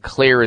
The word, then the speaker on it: Xlear: And Clear is spelled X-L-E-A-R. The X stands for xylitol.